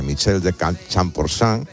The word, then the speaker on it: Michel de Champorsan: Pues mira, sí, vengo a exponer porque inauguramos una galería de arte de Michel de Champorsan, que es como se pronuncia correctamente, en el Corte Inglés del Capricho.